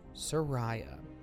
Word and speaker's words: Soraya: She said, 'My name is pronounced Soraya.'